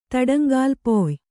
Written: ತಡಂಗಾಲ್ಪೊಯ್

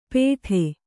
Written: ಪೇಠೆ

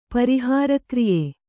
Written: ಪರಿಹಾರಕ್ರಿಯೆ

